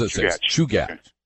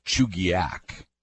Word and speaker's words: Chugach: No, it's Chugach. Okay. Now, there's a village just north of Anchorage called Chugach. – Yep. – Three syllables.